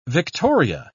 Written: victoria

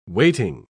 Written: waiting

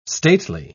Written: stately

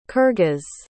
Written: kirghiz

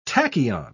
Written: tachyon

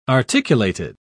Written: articulated

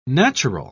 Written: natural

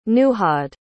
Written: nuhad

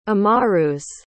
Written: amarus

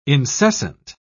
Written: incessant

